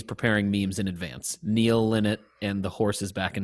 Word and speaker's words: Lynott: I think it's Lynott. – No, it's Lynott.